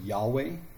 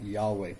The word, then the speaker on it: Yahweh: It has no vowels, so you really don't know how you're supposed to pronounce the word. So we use the word Yahweh, or we use the word Jehovah. Most scholars think Yahweh is the closer to the correct pronunciation.